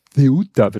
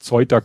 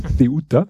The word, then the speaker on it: Ceuta: nämlich Ceuta wird das ausgesprochen, nicht Zeuta, Keuta oder sonst irgendwie, sondern Ceuta.